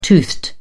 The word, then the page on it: toothed: British English